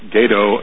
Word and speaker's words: Gato: It's G-A-T-O, which is Spanish for cat, but they don't pronounce it with the Spanish A. It's Gato and Balao.